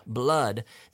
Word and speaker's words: blood: Proof, blood, blood. This is pronounced almost like an A.